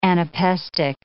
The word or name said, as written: anapestic